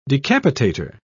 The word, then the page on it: decapitator: British English